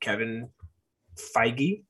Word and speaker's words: Feige: Kevin Feige, say it right? – Yep.